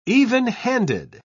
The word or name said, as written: evenhanded